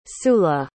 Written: sulla

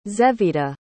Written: zevida

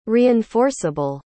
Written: reinforceable

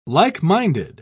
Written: like-minded

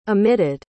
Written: emitted

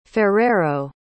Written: ferreiro

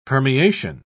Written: permeation

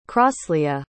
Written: crosleah

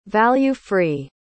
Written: value-free

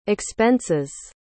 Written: expenses

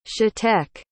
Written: shateque

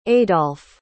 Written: adolf

